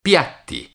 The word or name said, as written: piatti